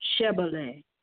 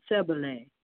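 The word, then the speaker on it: Shibboleth: And if he said, nay, then said they unto him, say now, Shebele. And he said, Sebele, for he could not frame the pronounce it right.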